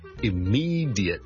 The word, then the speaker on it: immediate: Ladies and gentlemen, boys and girls, hi, 张故事又见。今日同大家讲一个好常用嘅一个字嘅英文发音，就系、是、咧 immediate, immediate，立刻立即。咁啊，有个听众问。 Diet 咧應該點樣讀得好啲？正常咧，我哋如果係好快咁讀咧，我哋可能會讀成 jit 嘅啫，jit jit jit，好似 j i t 咁嚟讀，jit jit jit，或者用一個好弱嘅 schwa 音，jit jit jit 或者 jit 都得，immediate immediate immediate，咁加埋個 ly 咧，我就會聽到咧，immediately immediately immediately。